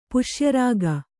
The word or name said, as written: ಪುಷ್ಯರಾಗ